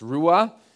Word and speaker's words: ruah: It's pronounced ruah.